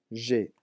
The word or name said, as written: j'ai